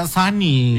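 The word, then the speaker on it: Sony: Iya, cinta pertama Suni kan. – Itu merek itu. Oh iya. Merek elektronik zaman dulu udah tutup. – Oh, enggak, memang gitu tulisannya, tapi bacanya Sani.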